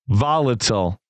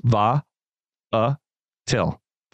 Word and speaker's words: volatile: Volatile. From the Oxford Dictionary. It's actually where I'm pulling this right now. It's an adjective. Three syllables. Va-a-til. Va-a-til. Adjective.